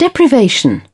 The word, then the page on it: deprivation: British English